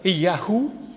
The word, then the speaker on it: YHWH: ce qui se prononce Yahou comme dans Nathaniahou,